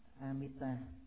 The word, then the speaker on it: Amita: Thì chữ A Di Đà là tiếng Phạn, nguyên âm đọc là Amita A Di Đà, dịch nghĩa là chữ Hán là vô lượng thọ và vô lượng quang.